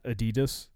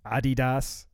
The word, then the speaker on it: Adidas: Is apparently, that's how you really pronounce it, not Adidas. Adidas, because it's a German company,